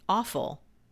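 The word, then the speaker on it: offal: Well, now, one word that I have heard over the years, I actually looked up the pronunciation because I'm like, okay, am I saying this right? Awful. It's O-F-F-A-L. – Oh, awful.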